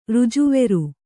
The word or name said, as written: ರುಜುವೆರು